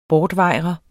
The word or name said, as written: bortvejre